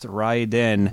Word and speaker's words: Raiden: My number four title is Raiden, or as it's properly pronounced, Raiden.